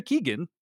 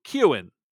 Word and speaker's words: Keoghan: And then Barry Keegan's name is not pronounced Barry Keegan. It is pronounced phonetically Key plus Owen, like Owen Wilson. So Keoghan, like Keoghan.